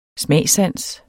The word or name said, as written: smagssans